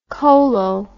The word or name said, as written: colo